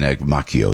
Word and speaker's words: Macchio: So I said, well, I might be able to get Chris Macchio, and Macchio is the way he pronounces it.